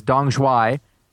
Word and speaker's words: Dong Zhuai: The book of the month for May, as you may have noticed on the site, is Dong Zhuai, and that is how to pronounce it. I checked by Joe Kubert.